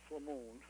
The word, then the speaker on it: Flamond: Am I pronouncing that one correctly? – Flamond. – Flamond. Oh, right. It's French.